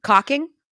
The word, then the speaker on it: caulking: I was just really hoping you would put pronounce the L in caulking. That'd be awesome. But hey, it's your show. – I mean, the L is silent.